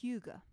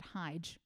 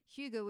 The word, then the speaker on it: hygge: And that is to practice hygge. So, what hygge is, it's actually spelled H Y Y G E. So, initially I thought you pronounced it Hyge.